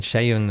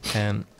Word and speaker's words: Seun: How do you say his name, Sean? – S-E-U-N, Shayun, they say. – Shayun.